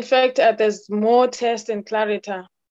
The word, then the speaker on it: Clariter: Am I saying that correctly? That company's name, Clariter. – Yeah, Clariter. – Okay. Yeah. What is the experience like working for Clariter and what have you learned so far during your time there? – I don't know where to start.